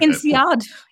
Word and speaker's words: INSEAD: You also studied at INSEAD. I think I said that right. – INSEAD.